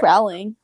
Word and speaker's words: Rowling: But honestly, like JK Rowling or JK Rowling, how do you pronounce her name? – I think it's Rowling.